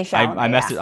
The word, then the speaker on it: Chalamet: Am I pronouncing it right? Right? Chalamet. – Yeah, Timothy Chalamet.